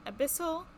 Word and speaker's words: Abyssal: and I chose Elvish and Abyssal.